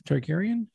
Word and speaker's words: Targaryen: Targaryen.